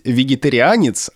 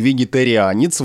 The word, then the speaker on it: вегетарианец: Дело не в ударении, а в произношении, потому что существует два варианта. На первом месте вегетарианец, вот это риа произносится, а на втором месте с мягким знаком, как будто бы, вегетарианец. Ну, по-моему, это так сложно даже произнести, но такой вариант допустим.